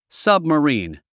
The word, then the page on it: submarine: sʌ̀bməríːn; サブマリーン